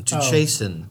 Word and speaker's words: chasten: Chasen. You want to spell it? – C-H-A-S-E-N? – S-T-E-N. Chasten.